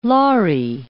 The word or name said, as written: laari